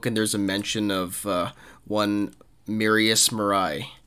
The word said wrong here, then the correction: Marais: who um, Marius Marais. Mar- yeah, Marius yeah. Marais. – Apologies for the pronunciation there.